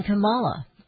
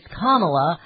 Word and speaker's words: Kamala: Everybody's pronouncing it, including her. She says exactly. – Kamala. – But she... – Don Lemon says, or Don Lemon says it's Kamala. I actually like Kamala. I think that sounds more sophisticated. I think she should go my way.